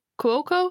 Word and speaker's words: Cuoco: It's Kaley Cuo- Cuoco.